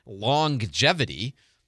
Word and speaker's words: longevity: Like I saw on Facebook, somebody says, why do we pronounce the G in, here's how I'll spell the word, L-O-N-G-E-V-I-T-Y. Why do we pronounce the G in that word twice? And my answer, and most people's answer is, well, we don't. It's not longevity. It's longevity. You pronounce the G once because there's one G.